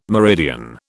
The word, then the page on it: meridian: British English